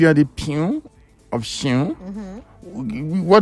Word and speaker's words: Siun: It's pronounced Siun. – SIUN. – If you're Shion. From Ogun State, Shion.